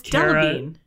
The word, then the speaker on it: Delevingne: It's Delevingne. – Delevingne? Oh my God, why am I making it more difficult than what it is? Okay, Cara Delevingne.